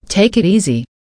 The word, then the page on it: take it easy: American English